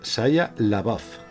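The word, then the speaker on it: Shia LaBeouf: Bueno, se, pronuncia Saya Labaf.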